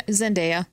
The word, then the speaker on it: Zendaya: Am I saying that right? – Yeah, Zendaya.